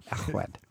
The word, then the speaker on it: Llechwedd: And when you have L is pronounced L, but two L's are pronounced L. – Llechwedd.